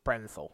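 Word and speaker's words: Bernthal: Brenthal, thank you.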